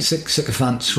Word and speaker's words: sycophants: Sycophants. – Oh, okay, sorry, I'm being thick, right. – Sycophants, rhymes with pants.